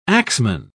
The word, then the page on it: axeman: British English